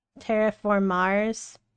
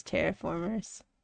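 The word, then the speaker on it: Terra Formars: Terraform Mars. Oh, really? I didn't know that was how you pronounce it. I just thought it was Terraformers. My bad! That is my bad.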